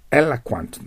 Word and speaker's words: eloquent: 発音がベロの先を上の歯に当たるね、 eloquent になります。